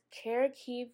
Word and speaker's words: Kharkiv: You know, I was listening to NPR and I heard about, I hope I'm not butchering this, but I believe it's Kharkiv, Ukraine.